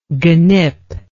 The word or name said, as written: genip